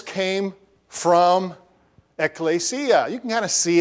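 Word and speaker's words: ekklesia (ἐκκλησία): But there's a word in the New Testament which is almost always translated church, and that's one up there, and it's pronounced ekklesia. Ekklesia.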